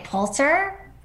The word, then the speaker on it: Pulitzer: People sometimes mispronounce the first syllable of this prize as Pule, but it's really Pull. – Okay, Pulitzer? – Helen?